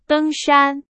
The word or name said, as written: 登山